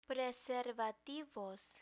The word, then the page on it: preservativos: Spanish